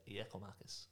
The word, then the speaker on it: Giakoumakis: I spoke to a guy in Greece and I was like, How do you pronounce it? And he was like, Yakomakis. I was like, Okay, cool go with that.